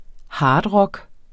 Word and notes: hardrock: ˈhɑːd-

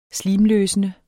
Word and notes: slimløsende: [ -ˌløːsənə ]

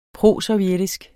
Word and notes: prosovjetisk: [ ˈpʁosɒwˌjεdisg ]; [ ˈpʁosʌwˌjεdisg ]

